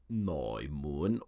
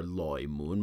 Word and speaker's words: Noi Mun: And then the last two characters, Noi Mun or Loi Mun. Most Cantonese, they don't like to pronounce the sound of N. They change it to an L.